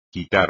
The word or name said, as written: quitar